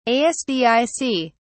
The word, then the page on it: Asdic: British English